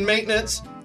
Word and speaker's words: maintenance: And I think I got the hardest words because there's a silent N in maintenance. And rhythm. – It's not silent. Maintenance. – You're saying it. – No, he said maintenance.